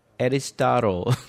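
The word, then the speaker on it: Aristotle: 아리스토텔레스는 어떻게 읽어요? 에리스타로. 그렇죠. 에리스타로, 플레이로 이렇게 읽어요.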